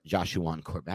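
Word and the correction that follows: JaShaun Corbin: How do you pronounce his name? – Jashawn Corbin. – Jashawn. I don't know why I call him Joshuan. Jashawn Corbin.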